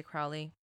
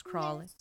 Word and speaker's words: Crowley: Many Wiccans agree with the definition of magic offered by ceremonial magicians such as Aleister Crowley, Crawley. I always want to say Crowley. It's actually pronounced Crawley.